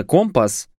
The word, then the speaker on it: компас: На штурманском столике, там, где по идее должен находиться компас, непременно с ударением на «а».